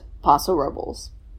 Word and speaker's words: Paso Robles: Also, when you call like the city hall, they pronounce it Paso Robles. – Yeah. – That is how it is pronounced.